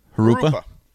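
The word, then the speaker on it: Jurupa: Harupa.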